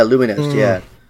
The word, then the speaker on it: Lumines: That's right, Rez, and I think Lumines, I don't know Lumines. – How to pronounce it. Yeah, Lumines, So he's got a history with this whole like trippy audio visual sensory synesthetic, you know, stuff.